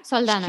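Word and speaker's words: Saldaña: Yeah, Saldana.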